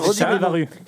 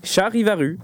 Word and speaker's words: Charivaru: Oui, c'est Charivaru. – Charivaru. – C'est en deux mots. – Oui. – Wow, comme Charivari, mais voilà, détourné.